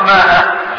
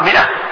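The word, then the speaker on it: مائة: هذه الألف تكتب وما تلفظ، يعني تكتب هكذا ميم ألف بعدين همزة ها، لكن ما تقرأ ماء، تقرأ مئة، تقرأ مئة، تكتب الألف ولا تلفظ ولا تقرأ.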